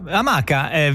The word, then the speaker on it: amaca: Amaca viene, si dice amaca semplicemente perché nella lingua taino di questa popolazione antica che viveva nei Caraibi si leggeva amaca. Si scriveva amaca con l'H davanti e con la K, a differenza nostra, ma l'accento andava sulla seconda A. Quindi amaca e non amaca.